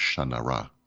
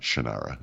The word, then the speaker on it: Shannara: And uh, that name is pronounced Shanara. it's like, God damn it, not Shannara, as I just said.